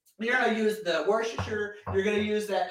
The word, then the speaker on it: Worcestershire: I mean, hey, Google, how do you pronounce Worcestershire? – That's pronounced Worcestershire.